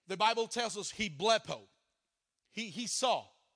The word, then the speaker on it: blepo: It's spelled B-L, short E, P-O. You pronounce it blippo, which is just to see something with your natural eyes.